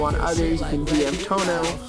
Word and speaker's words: Toño: The tilde is silent. You say it aloud, so it's just Tono.